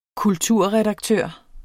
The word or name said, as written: kulturredaktør